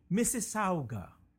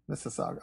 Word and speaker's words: Mississauga: But now a research team out of the University of Toronto, how do you say that, Massagua? – Oh, Massagua. – I don't speak Canadian. – Mississauga. – Mississauga. I don't speak Canadian, so forgive me.